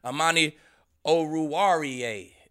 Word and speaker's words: Amani Oruwariye: Then Amani Oruwariye is how you say it, man. You're gonna learn how to say it. You're going to know his name, boy.